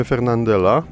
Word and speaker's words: Fernandel: Ale z kolei podobnie zakończonych jest wiele nazwisk francuskich, z tym, że mamy wyrazy francuskie bardzo często, nie wiem czy wszystkie, ale akcentuje się na ostatnią sylabę. Jeśli mamy Fernandela, to Francuzi mówią Fernandel, wtedy te francuskiego pochodzenia nazwiska nie redukujemy tego, ale piszemy i mówimy Fernandela.